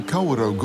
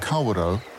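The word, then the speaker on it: Kawarau: As we mentioned earlier, the Kawerau Gorge is the only known outlet for Lake Wakatipu. The Maori name Kawerau, often mispronounced Kawera, literally means many rapids.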